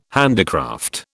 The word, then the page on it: handicraft: British English